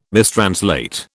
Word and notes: mistranslate: British English

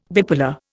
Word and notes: bipolar: Indian

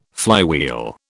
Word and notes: flywheel: British English

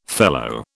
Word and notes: fellow: British English

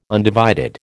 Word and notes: undivided: American English

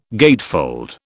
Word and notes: gatefold: British English

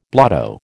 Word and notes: blotto: American English

